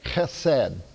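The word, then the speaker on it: chesed (חסד): and this is how you say it in Hebrew, it's called chesed. And sometimes a real Hebrew will pronounce it chesed, okay? So you'll see it written in English, C-H-E-S-E-D. Sometimes you'll run across it in your Bible books, H-E-S-E-D, chesed.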